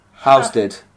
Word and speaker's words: Halstead: Well, actually, if you really want to say Essex, as a lot of people say, you want to don't pronounce the L. Halstead.